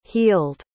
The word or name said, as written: healed